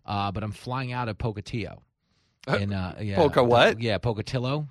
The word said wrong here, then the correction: Pocatello: Did I say it wrong? – Pocatello.